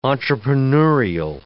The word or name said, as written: entrepreneurial